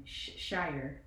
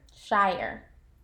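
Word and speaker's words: Shirer: I'm in agreement. Shire. – Shire. – That's what I'll go with. Priscilla Schreier. – Schreier, but there's an R.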